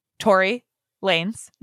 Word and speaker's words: Tory Lanez: Specifically made fun of the foot that was shot by Tory Lanez, not Tony Lanez, as I initially pronounced his name on this podcast.